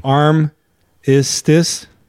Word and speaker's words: Armistice: Armistice. – Well, okay. The way, yes, that's how you pronounce it, and that's how it's said.